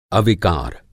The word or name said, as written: अविकार